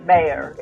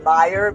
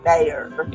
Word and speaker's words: Meijer: Yeah, Mayer. It's not, I always want to pronounce it Meyer because of the I, but it is Mayer.